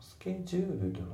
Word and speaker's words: scheduled: で、あと発音なんだけど、まあ、スケジュールドのところですね。スケジュールドの。この T が抜けますね。